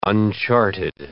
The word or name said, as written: uncharted